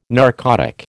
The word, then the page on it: narcotic: American English